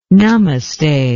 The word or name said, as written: namaste